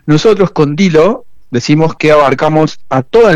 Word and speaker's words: Dillo: Decime, este Dilo que se escribe DILLO es. – Exacto, Ajá. – DILLO.